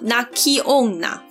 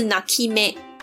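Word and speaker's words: 泣き女: 附带一提，日本古代也是有孝女白琴，日文叫做哭女，诶，汉字是读作哭泣的泣的泣女，读作 naki onna 或者是 naki me 啊。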